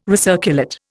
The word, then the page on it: recirculate: Indian